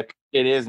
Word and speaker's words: Piquerez: Eu até falei para o Tiagão, eu ia mandar uma mensagem para o Tiagão, que agora eu descobri como se pronuncia ah. Piquerez. É realmente Piquerez. É, pode chamar de Pique, de o, o, o Joaquim pode chamar de Joaco, Joaco, uhum. mas é Piqueires mesmo.